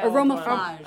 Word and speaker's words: Aromaflage: Oh, Armor Flage or something. How do you say it? – Aromaflage. I feel like I'm going to break it.